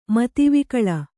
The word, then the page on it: ಮದವಿಕಳ: madavikaḷa